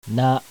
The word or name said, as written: NA